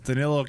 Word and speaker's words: Danilo: Danilo Calinari.